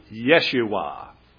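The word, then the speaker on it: Yeshua (ישוע): The name Jesus, if you would get back to the original pronunciation, scholars say it would be Yeshua.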